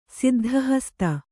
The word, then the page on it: ಸಿದ್ಧಹಸ್ತ: Kannada